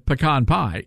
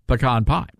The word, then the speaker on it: pecan: By the way, we have also apparently we have already established that the correct pronunciation for the word pecan pie is pecan pie, not pecan pie, which we discovered means something totally different. But also, it is not pecan pie, but pecan pie, ladies and gentlemen.